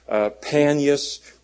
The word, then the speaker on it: Panias: Actually, it was called Panias, but because the Arabs can't pronounce P's, they call it Banias.